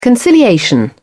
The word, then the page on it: conciliation: British English